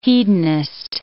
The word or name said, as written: hedonist